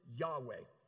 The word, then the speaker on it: Yahweh: It's YHWH. We pronounce it Yahweh.